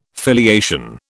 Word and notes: filiation: British English

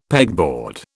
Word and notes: pegboard: British English

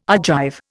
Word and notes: Argive: Indian

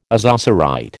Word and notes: ozocerite: American English

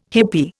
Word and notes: hippie: Indian

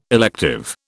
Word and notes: elective: British English

